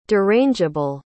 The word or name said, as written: derangeable